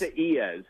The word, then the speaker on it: Isaias: You don't think it would sound like Isaias the way it's spelled, but that's how it's pronounced.